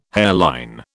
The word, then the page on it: hairline: British English